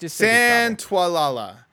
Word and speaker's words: Santaolalla: Just Santaolalla.